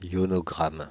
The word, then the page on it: ionogramme: /jɔ.nɔ.ɡʁam/; France (Île-de-France)